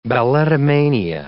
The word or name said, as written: balletomania